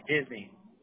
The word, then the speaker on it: Disney: Disney. – Can you spell that for me, please? – D-I-S-N-E-Y.